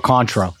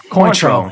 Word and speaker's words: Cointreau: Triple sac or Contro? – Cointreau.